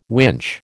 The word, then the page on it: winch: American English